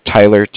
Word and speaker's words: Tyler: Like I said before, my name is Tyler, T-Y-L-E-R, and I'm calling with Cardiff. It's spelled C-A-R-D-I-F-F, as in Frank.